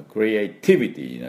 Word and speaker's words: creativity: クリエイのところ、クリエーションということで、普通の名詞だと、クリエイトとかだけだと最初にアクセントがあるんですけど、クリエイティビティになりますと、これは横に移りまして、ティビティが、発音になります。